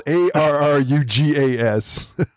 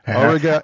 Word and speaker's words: Arrugas: It's spelled A R R U G A S. – Arrugas.